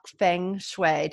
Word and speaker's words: feng shui: So we are going to talk feng shui.